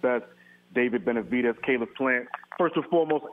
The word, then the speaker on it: Benavidez: Benavidez and my man Caleb Plant, they're going to get it on tonight.